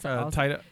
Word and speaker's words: Taika: It's Taika.